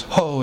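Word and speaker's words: ho (ὁ): We pronounce it as ho. It's a rough breathing.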